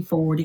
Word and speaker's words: Forde: On LinkedIn, it's uh, Tracy Forde. If you simply look for my name, and Ford is spelled with an E on the end, F O R D E.